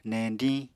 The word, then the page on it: neñdiñ: Apatani